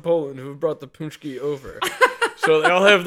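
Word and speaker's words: pączki: It's spelled P A N. – It's spelled no. It's spelled P-A-C-Z-K-I. That's Punchki.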